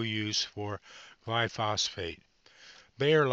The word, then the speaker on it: glyphosate: While Bayer is courting Monsanto, Monsanto may see, I'll pronounce this word, glyphosate pulled from European use. That's glyphosate, pulled from European use and availability, following the refusal by the European Union nations to extend its license as a herbicide.